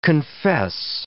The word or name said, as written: confess